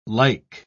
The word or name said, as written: like